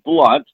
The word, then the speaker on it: Blount: And by the way, my last name is pronounced Blount, although it's spelled B-L-O-U-N-T. Most people call me Blount, but you can call me Blount if you want to.